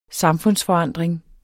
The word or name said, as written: samfundsforandring